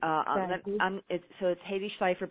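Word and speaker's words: Hedy Schleifer: So it's Haydee Schleifer, but I'm going to spell it for everyone.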